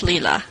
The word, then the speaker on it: Lilia: My name is actually it, it's spelled Lilia, but it's Lila. The I is silent.